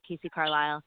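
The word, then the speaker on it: Kacee Carlisle: I'm on Instagram at Casey Carlisle.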